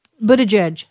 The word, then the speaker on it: Buttigieg: Buttigieg.